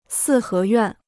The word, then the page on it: 四合院: sì hé yuàn